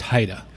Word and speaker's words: HIDTA: It's pronounced HIDTA. – HIDTA? Okay, HIDTA. Perfect.